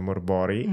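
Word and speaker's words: Mörk Borg: Io ho provato Morbori, tra l'altro dalla persona che mi ha insegnato che si dice Morbori, mm-hmm. e che dovrebbe essere la vera pronuncia svedese.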